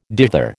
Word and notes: dither: American English

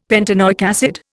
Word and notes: pentanoic acid: Indian

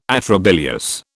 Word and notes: atrabilious: British English

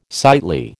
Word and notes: sightly: American English

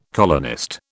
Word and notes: colonist: British English

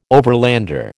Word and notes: overlander: American English

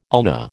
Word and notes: ulna: American English

